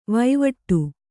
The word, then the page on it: ವೈವಾಟಿ: vaivāṭi